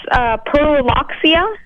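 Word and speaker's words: Pyrrhuloxia: I'm guessing it's Pearloxia. – Yes, and you even pronounced it correctly, I believe, as well.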